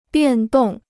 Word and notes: 变动: biàn dòng

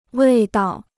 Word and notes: 味道: wèi dao

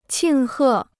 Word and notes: 庆贺: qìng hè

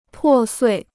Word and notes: 破碎: pò suì

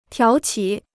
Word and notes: 挑起: tiǎo qǐ

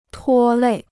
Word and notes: 拖累: tuō lěi

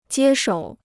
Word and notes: 接手: jiē shǒu

